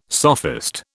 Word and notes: sophist: British English

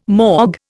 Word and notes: mog: Indian

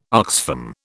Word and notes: Oxfam: British English

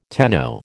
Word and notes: tenno: American English

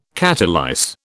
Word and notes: catalyse: British English

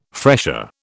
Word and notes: fresher: British English

